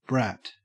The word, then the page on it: brat: Australian English